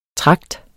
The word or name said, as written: Tragten